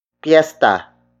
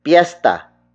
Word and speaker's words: piyesta: Piesta is spelled as P-I-Y-E-S-T-A. It means festival or fiesta. We also borrowed it from Spanish, but we spelled it according to how Tagalog speakers would pronounce it. May piesta means there is a festival. An alternate spelling of piesta is P-I-S-T-A or pista.